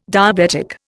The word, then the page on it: diabetic: Indian